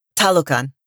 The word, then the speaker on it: Talokan: Talokan.